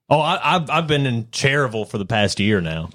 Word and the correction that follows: Cherryville: It's actually Cherryville. That's how it's pronounced.